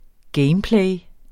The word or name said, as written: gameplay